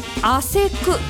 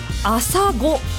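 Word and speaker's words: 朝来: 我が町舞鶴市では汗くと読みますが、兵庫県だと朝ごと読みます。さらに和歌山県となるとあっそと読むんですよね。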